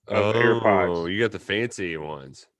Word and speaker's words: AirPods: Uh, and then I bought uh, how you say it? earpods? AirPods, yeah. AirPods.